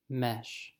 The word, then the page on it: mesh: American English